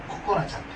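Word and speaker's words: 心絆: 心の絆と書いて、心、ココナちゃんという、ココナちゃんですね。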